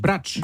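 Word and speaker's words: Brač: Bratsch.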